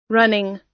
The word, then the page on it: running: American English